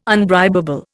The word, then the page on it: unbribable: Indian